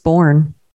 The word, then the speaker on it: Boerne: They're in, I don't know how you pronounce it, but it's Boerne, B-O-E-R-N-E. – Maybe it's just born. – Maybe it's born. Like Boerne, how are things down in Boerne?